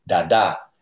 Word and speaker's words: Dada: And the Virgin of the Rosary is equated with a Yoruba spirit called Dada, D-A-D-A, with an accent on the second A, Dada.